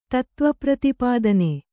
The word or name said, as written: ತತ್ತ್ವಪ್ರತಿಪಾದನ